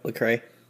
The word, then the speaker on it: Lecrae: Lecrae? Is that how you say his name? – Yep, Lecrae.